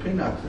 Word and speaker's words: pinax: It's shnei luchei pinkas. Pinax? No, you mean there's an alternative reading of it? – Not alternative. The word is pinax. It's not a Hebrew word. – Pinax or pinkas? – Pinax.